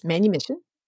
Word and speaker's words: manumission: I had never heard the term manumission. I assume that's how you pronounce it. – Yes, manumission.